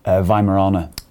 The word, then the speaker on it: Weimaraner: Give me the pronunciation of your dog's breed again. – Weimaraner. Spelt with a W. – Weimaraner.